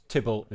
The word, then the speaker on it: Thibault: Is it Thibault? – Thibault. – Okay. This is Thibault, Drama. There we go. That was Thibault. – Thibault. – Is that right? – Thibault.